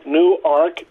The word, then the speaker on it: Newark: Well, as you said, we are in Newark, Delaware, not Newark. Newark, oh, for Pete's sake. Newark? How do you say it? – Newark, just like... – A new ark.